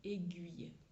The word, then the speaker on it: aiguille: Et la prof de phonétique euh, a dit bah non, sachez qu'en français standard, donc la norme standard internationale qui est établie, c'est le son UI aiguille qui a été retenu et pas le son OU.